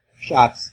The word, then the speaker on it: Schatz: is that right? Schatz? – Schatz. – Schatz. Schatz. – Yeah, shots.